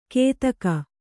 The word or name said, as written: ಕೇತಕ